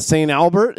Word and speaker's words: St. Albert: I stayed in St. Albert.